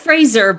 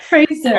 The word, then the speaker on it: Fraser: Was it Fraser or Fraser? – It's Fraser, but Fraser. Honestly, it doesn't really matter.